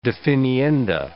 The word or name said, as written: definienda